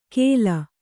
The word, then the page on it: ಖೇಲ: Kannada